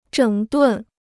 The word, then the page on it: 整顿: zhěng dùn